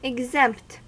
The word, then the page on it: exempt: American English